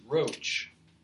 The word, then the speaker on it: Roche: Amen in ninth grade, my English teacher was named Mrs. Roach. It was R O C H E. I don't know why she didn't try to pronounce it Roche or something like that, but if you can imagine the amusement we got out of that pronunciation.